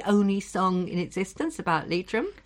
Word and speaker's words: Leitrim: The proper pronunciation is Lovely Leitrim. – Yeah, three syllables in each word.